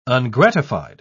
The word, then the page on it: ungratified: British English